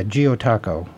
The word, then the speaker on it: Gyotaku: It's Gyotako, which is spelled Gyotaku, which in Japanese means fish rubbing.